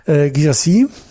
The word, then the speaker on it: Guirassy: Gisassi, haben wir ihn richtig ausgesprochen? – Das klang sehr gut. – Das klang doch ganz gut, ja.